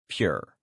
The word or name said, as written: pure